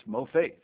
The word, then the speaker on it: mopheth: M-O-F-A-I-T-H. But that's the pronunciation. It's actually the Hebrew spelling is M-O. P-H-E-T-H, pronounced mofaith. And it's mofaith. It's emphasis on the other one, on the second syllable, because that's the Hebrew.